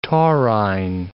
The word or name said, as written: taurine